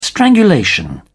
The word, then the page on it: strangulation: British English